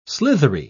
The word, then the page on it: slithery: British English